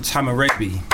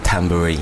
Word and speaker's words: Tamarabi: He goes by the name Tamarabi. – Hey, man, like. – I have pronounced that right, haven't I? – Yeah, that's right.